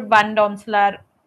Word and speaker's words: Van Domselaar: I would put Van Domselaar at that spot.